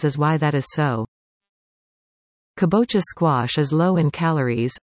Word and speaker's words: kabocha: Some of the varieties available included acorn, spaghetti, butternut and kabocha, the latter of which I purchased. Kabocha, pronounced ka-bo-cha, squash is a popular variety in Asian countries, such as Thailand, Korea and Japan.